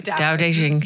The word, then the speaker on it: Tao Te Ching: Tao Te Ching.